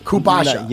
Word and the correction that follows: kombucha: It's not Kubasha. Am I saying it right? – You're kombucha. – Right. – Kombucha. – Kombucha. You betcha.